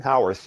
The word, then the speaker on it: Howarth: Howarth, yeah.